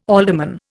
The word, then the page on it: alderman: Indian